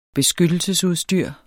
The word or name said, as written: beskyttelsesudstyr